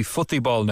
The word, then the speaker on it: futebol: To get the pronunciation right, if I was in Brazil right now, it would be Futebol Nation.